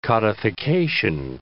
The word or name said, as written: codification